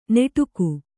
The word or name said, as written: ನೆಟುಕು